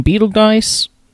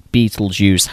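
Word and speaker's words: Betelgeuse: Is his name Beetlegeist or Beetlejuice? – Juice, just because it's fucking phonetically spelled for all you savages. – Yeah. Because this is how you actually spell his name, but the movie is called Beetlejuice, how it sounds, because I guess that's more interesting.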